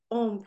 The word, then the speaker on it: Om: And to pronounce Om properly, the sound vibration is pronounced like home. So it's home. With a Midwestern accent.